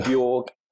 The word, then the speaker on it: Borge: Borge, Fagerli, is that good enough? Is that about right, or is that completely off? – Well, sort of. It's Borge, yeah, like Berg.